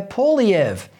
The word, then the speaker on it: Poilievre: a bloke called Pierre Poiliev. His surname is spelt P-O-I-L-I-E-V-R-E, Poiliev.